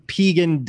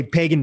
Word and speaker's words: pegan: Just first tell the listeners, like, where did the term pegan diet come from? And are you upset ever that people mispronounce it and they say pagan diet? – No, I'm not upset.